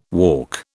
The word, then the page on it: walk: British English